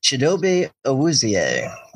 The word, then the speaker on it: Chidobe Awuzie: Chidobe Awuzie, I think is how you pronounce that? – Chidobe Awuzie.